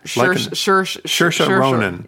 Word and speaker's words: Saoirse: Saoirse? – Like Inertia. – Saoirse Ronan.